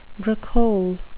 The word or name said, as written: bricolage